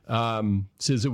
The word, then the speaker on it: CISA: So I think maybe the highest profile part of this was the establishment of what's everybody's favorite agency to debate the pronunciation of, CISA, which is the correct pronunciation, by the way. CISA was formed in 2019.